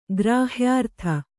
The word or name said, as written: ಗ್ರಾಹ್ಯಾರ್ಥ